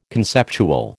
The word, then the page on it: conceptual: American English